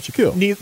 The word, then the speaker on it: Shaquille: Because when I heard Shaquille O'Neal, never, ever heard of anybody being named Shaquille. – Neither had I. – Yes. – And it's an awesome name. And it's also very cool that it rhymes with O'Neal.